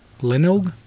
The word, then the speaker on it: Glenelg: near, how do you say that? – Glenelg, 11 kilometers southwest of Adelaide, South Australia.